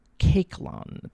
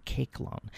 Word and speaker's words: Koechlin: First, we're going to hear music by Charles Caiclin, that's spelled K-O-E-C-H-L-I-N, Caiclin.